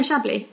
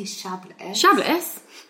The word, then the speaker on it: Chablis: Yeah, Chablis. You don't say the S, I don't think. – I don't say Chablis.